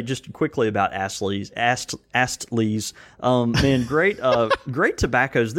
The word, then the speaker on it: Astley's: And do you pronounce the T? – I'm trying to. – Okay. Well, I was just curious because it sounded like you were saying Astley's, and I was like, Astley's. – Yeah. – I was like, there's Astley's. I know Astley's, but Astley. Astley. You know, it's funny because it sounds the same way when you pronounce the T or double.